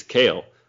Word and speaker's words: Kale: I said Nick Colley. And they're just like, oh, that it's Kale.